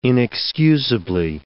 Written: inexcusably

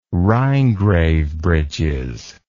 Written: rhinegrave breeches

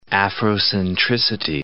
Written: Afrocentricity

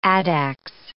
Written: addax

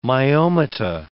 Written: myomata

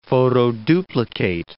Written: photoduplicate

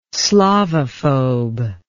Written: slavophobe

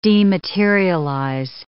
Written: dematerialize

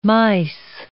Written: mice

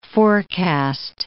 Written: forecast

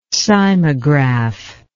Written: cymograph

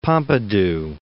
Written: Pompidou

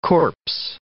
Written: corpse

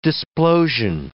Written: displosion

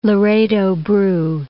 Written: Laredo Bru

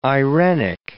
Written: irenic

